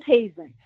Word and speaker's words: Hasen: Is it Hasten? – It's Hazen. – Hazen, okay. – Rhymes with raisin.